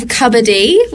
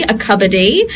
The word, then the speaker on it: cupboardy: Okay, we have cupboardy, which is for a cup of tea, but it has to be pronounced with the B, a cupboardy.